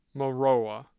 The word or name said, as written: Maroa